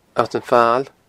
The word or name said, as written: Allt an Fail